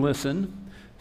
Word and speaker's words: listen: The T in listen stands for tune in. Tune in. And I think it's interesting that the word listen has a silent letter in it. The T in listen is silent.